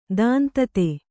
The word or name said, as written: ದಾಂತತೆ